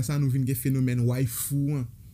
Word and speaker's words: waifu: dans la communauté nan communauté otaku ou bien communauté wibs lan waifu c'est wife en anglais wife qui di ma, femme et madame mariée ok wife et puis c'est prononciation wife en japonais